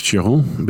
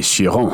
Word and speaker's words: Chiron: Yves, yeah, Yves Chiron, but it'd be okay. Yves, Y-V-E. – Yeah. – And you have to say Chiron.